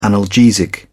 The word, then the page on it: analgesic: British English